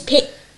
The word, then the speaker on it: Pichon: And the author of this is L. Picon. Pichon? – Liz Pichon.